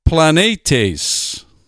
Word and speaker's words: planetes: In fact, the 4107 is pronounced planetes. Planetes. Sounds a lot like planet, doesn't it?